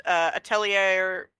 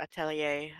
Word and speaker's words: Atelier: Yeah, I Google image searched Atelier gameplay, and this is what this is what came up. Or Atelier, I guess. To not use the boorish American mispronunciation.